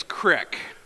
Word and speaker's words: creek: By the way, if you grew up in Kansas like I did, then you know that sophisticated people pronounce that word creek as crick. All right, that's the proper way to say it.